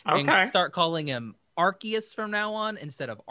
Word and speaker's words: Arceus: And then the big one that came out was Pokemon Legends, and I guess now it is pronounced Arceus. I always thought really? – That's what they kept saying in the Pokemon Presents. They kept saying Arceus, like, in the whole thing.